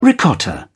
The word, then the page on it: ricotta: British English